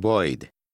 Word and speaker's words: Boyd: Sí, me llamo John Boyd. – ¿Cómo se escribe, por favor? – B-O-Y-D.